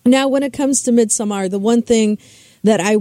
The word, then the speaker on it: Midsommar: And what I like about Midsummer, and it's actually pronounced Midsommar, if you want to be literal about it.